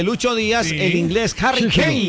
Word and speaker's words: Harry Kane: Fue el que anotó el día de hoy el Harry Kane. Harry. Como en la película Harry Potter.